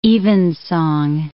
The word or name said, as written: evensong